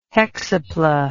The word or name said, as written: hexapla